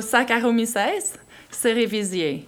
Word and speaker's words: Saccharomyces cerevisiae: So Saccharomyces cerevisiae.